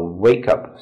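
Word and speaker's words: wake-up: And this is a wake up, a wake up. So the stress is on the first part wake a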